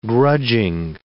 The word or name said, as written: grudging